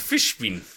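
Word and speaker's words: Fishbein: Morris Fishbin. – I looked up how to pronounce it, Fishbin.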